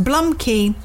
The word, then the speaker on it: blumke: And the meaning for blumkey, spelt B-L-U-M-K-E, pronounced blumkey, is giving your fella a blowjob whilst he's having a number two.